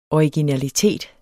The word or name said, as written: originalitet